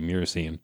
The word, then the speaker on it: myrcene: After that, the next one is myrcene or myrcene. Again, some viewer comments on how you say it, M-Y-R. So I say myrcene.